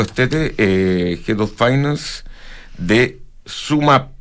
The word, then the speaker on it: SumUp: Se escribe Sumup, ¿ya? S-U-M-U-P, pero se pronuncia Sumap.